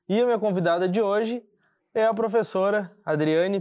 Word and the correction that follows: Adriana: Pires Neves. Adriana Pires Neves. Quase que eu falei errado.